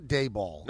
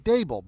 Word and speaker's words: Daboll: It's not Daboll. – No, that's what they Daboll. – Say. – Yes. – They say Daboll. – It's like Abel, Daboll.